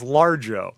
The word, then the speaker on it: Largo: It's pronounced Largo.